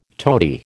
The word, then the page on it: tody: American English